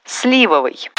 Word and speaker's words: сливовый: Сливовый. Латте со сливовым пирогом грамотный официант вам принесет охотнее, чем латте со сливовым.